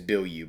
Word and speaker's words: Bilyeu: I think it's Bill Yu, by the way. That's how you say his name.